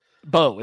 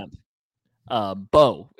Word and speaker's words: Mbow: It's just Bow. Like bow and arrow. There's no so, but it's spelled MBOW, but you don't say the M. So Bow.